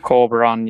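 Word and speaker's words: Corberán: I think the manager, I can't pronounce his name, Corberan or something, is it? – Corberan, yeah.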